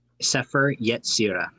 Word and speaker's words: Sefer Yetzirah: Sefer Yetzirah.